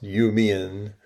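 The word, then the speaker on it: Iu Mien: There are now tens of thousands of Iumien, pronounced Iumien, in the U.S., with many attending universities or starting businesses.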